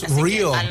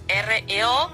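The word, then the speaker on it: REO Speedwagon: Pero no es Rio, no se pronuncia como Rio. – No, es R.E.O. letra por letra. – O sea que sería R.A.O. – Claro, R.E.O. letra por letra. No se pronuncia Rio.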